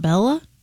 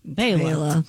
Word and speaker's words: Bela: Born in 1958, the great banjo player, Mr. Fleck, first name B-E-L-A. – B-E-L-A. It's not Bella? – It's Bela. – Bela.